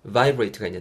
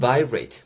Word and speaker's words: vibrate: V-I-B-R-A-T-E. vibrate가 있는데요. 그래서 put your phone, put your phone on vibrate mode라고 이해하시면 될 것 같습니다. 발음하실 때 주의하실 점은 vibrate보다는 vibrate. V 사운드는 양입술이 절대 윗입술, 아랫술이 안 닿는다라는 거.